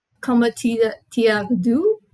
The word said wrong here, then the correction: Comment te dire adieu: Yes, comment te dire adieu? – Yeah, comment te dire adieu.